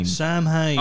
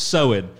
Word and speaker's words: Samhain: How do you pronounce that? – Samhain. Oh, no. Pronounce Samhain. Samhain.